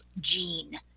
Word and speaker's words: Jeanne: The book that we are discussing today is her most recent release entitled Gene, like a person's name. I don't know if I'm enunciating well, but it's spelled J-E-A-N-N-E, Gene, a person's name.